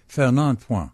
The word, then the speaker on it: Fernand Point: I want to say Bernard Point, but I think I'm wrong. I can't fully remember the name. – No, you mean Fernand Point. – Sure. – It's spelled point. It's pronounced point. Fernand.